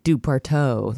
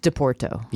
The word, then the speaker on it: Doporto: Are you sure you're pronouncing that correct? – Well, it's D-O-P-O-R-T-O. What would you say? – Oh, I thought it was like DuPorto, like a French... – I'm guessing it's Italian. – That's a unfortunate sounding DoPorto. – Wait, maybe... – I'm looking it up now. – Portuguese. – DePorto, probably. Pronounced DePorto.